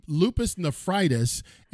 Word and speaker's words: lupus nephritis: And how do you pronounce that again? – Lupus nephritis, and it started scarring your kidneys.